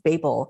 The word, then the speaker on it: Babel: So I it's really funny that you say that, because I also thought that her last name was Babel, but it's actually pronounced Babel, kind of like label.